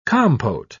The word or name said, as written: compote